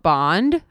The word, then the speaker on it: Bande: And I actually recently started doing more at-home workouts with this company called Bond, which it's spelled B-A-N-D-E, if anybody's interested.